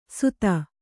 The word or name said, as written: ಸುಧ